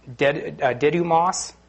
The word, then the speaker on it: Didymus: Now Thomas, one of the 12, called the twin, in other translations is Didymus, is how you pronounce it.